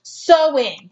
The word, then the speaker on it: Samhain: Yes, and it is a tradition that originated with the ancient Celtic festival of Samhain. That's how you say it, but it's spelled like it sounds like Samhain. It's not.